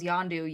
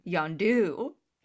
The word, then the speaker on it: Yondu: Also, the funniest thing about Sylvester Stallone being in the movie is that everyone calls Yondu, Yondu, and then Sylvester Stallone at the end calls him Yondu. Like... It's just got the emphasis on the wrong syllable.